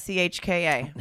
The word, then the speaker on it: Hauschka: It's Hauschka.